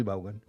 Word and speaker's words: Vaughan: ¿Cómo se dice? ¿Vaughan? ¿Vaughan? – ¿En qué idioma quieres? – En español sería Vaughan. – ¿Colgate o Colgate? – Colgate. – Vale, entonces Vaughan. – Ah, Vaughan. Sí. Vale.